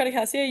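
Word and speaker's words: de Casier: And I'm also going to be playing Lucky by Erica DeCasier. Is that how you say her name? – Erica DeCasier, yeah.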